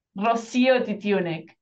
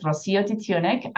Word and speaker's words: Rocío Titiunik: How do I pronounce it? – Rocío Titiunik. – Rocío. I'm gonna say Rocío.